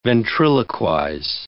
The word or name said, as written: ventriloquize